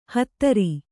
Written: ಹೋತೃ

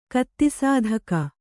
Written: ಕತ್ತಿಸಾಧಕ

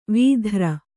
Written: ವಿದೂರ

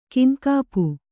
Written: ಕೈನೆಗಪು

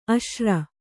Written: ಅಶಿರ

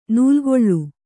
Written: ನೆಲೆಕೊಳ್

